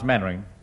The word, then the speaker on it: Mainwaring: Actually, it's pronounced Mannering. – Then why the hell do you spell it Mainwaring?